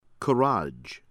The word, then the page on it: Karaj: American English; from Iran